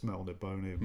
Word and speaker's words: Melniboné: It's got a thing on a hyphen on the end, or whatever it's called. – Yeah. – So it's Melniboné.